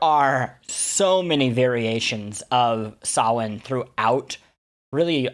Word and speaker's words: Samhain: And we'll be focusing largely on, and here's where I'm glad that I looked up how to pronounce this, Samhain, or Samhain, which is spelled Samuin, or Samhain. So, sounds nothing like it looks.